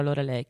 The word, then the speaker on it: Lorelei: Eccoci qua, Cocteau Twins, la Lorelai, o Lorelei, perché la pronuncia sarebbe la Lorelei.